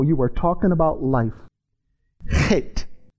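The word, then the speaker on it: het (חית): It's the word, it's pronounced het. Het.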